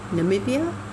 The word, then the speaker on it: Namibia: Namibia? Yeah, that's how you say it.